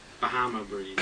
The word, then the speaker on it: Bahama Breeze: You know, it's not like Obama Breeze. – He has to picture it. – Obama Breeze or whatever you call it. – Bahama Breeze.